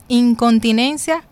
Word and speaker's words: incontinencia: Y es incontinencia urinaria. – ¿Cómo se llama? – Incontinencia. – Incontinencia, ok, ok, lo dije mal. Incontinencia.